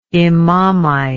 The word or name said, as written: imamite